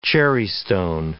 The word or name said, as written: cherrystone